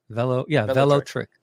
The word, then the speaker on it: Velotric: Velotric? I'm saying that right? Velotric. Yeah, like Hello and Trek, like electric.